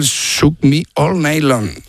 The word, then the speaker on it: Shook Me All Night Long: Qué me cuesta, el Shook Me All Night Long. – Ahí mucho mejor. Muy bien.